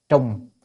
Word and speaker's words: trùng: Trùng hành hội tập, trùng chữ này đọc là trùng chứ không phải đọc trọng được, trọng là nặng, còn trùng là làm lại á.